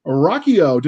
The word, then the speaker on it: Ruocchio: So without further ado, let's introduce you to our guest, Christopher Ruocchio.